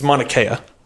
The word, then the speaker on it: Mauna Kea: Manauka? – Mauna Kea. – Monka? – I don't know. – It's Mauna Kea. – Mauna Kea. – Yeah.